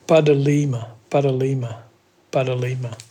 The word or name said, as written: badhaalima